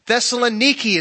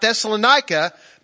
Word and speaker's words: Thessaloniki: Thessaloniki is what it's called today. Not Thessalonica, but Thessaloniki.